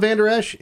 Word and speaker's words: Vander Esch: Vander Esch.